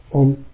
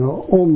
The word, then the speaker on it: Om: There are three syllables, A, U, Ma, you know, then become Om.